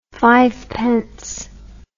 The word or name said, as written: fivepence